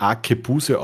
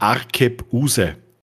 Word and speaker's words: Arkebuse: Das waren eigentlich zwei Wörter ähm, und zwar spricht man es nicht Akebuse aus, sondern Arkebuse.